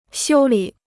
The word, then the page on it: 修理: xiū lǐ